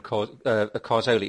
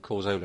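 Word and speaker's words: Cazorla: Jonathan Pearce and his pronunciation of Carzola. You know, he pronounces the th in Carzola instead of the z, trying to sound all poncy and foreign and hipster.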